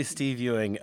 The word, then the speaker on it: Steve Ewing: I think I'm pronouncing it right, Steve Ewing. – It is definitely Steve Ewing.